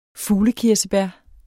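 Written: FUGLEKIRSEBÆR

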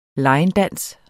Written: linedance

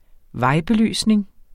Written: VEJBELYSNING

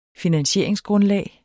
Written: finansieringsgrundlag

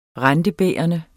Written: rentebærende

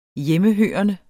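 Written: Hjemmehørende

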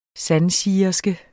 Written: sandsigerske

